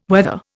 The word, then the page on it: wether: Indian